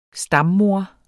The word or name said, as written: stammoder